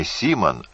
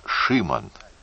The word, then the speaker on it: Шимон: Теперь уже не Маркиш, а Маркиш. Ударение сменилось. И не Симон, а Шимон.